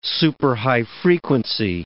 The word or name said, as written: superhigh frequency